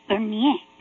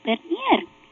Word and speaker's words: Bernier: I don't know how to say the name because it should be Bernier. In French, you don't pronounce the last R. But because I grew up in Puerto Rico and speaking Spanish, I say Bernier.